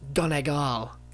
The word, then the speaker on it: Donegal: Typical, but it's pronounced Donegal.